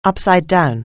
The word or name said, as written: upside down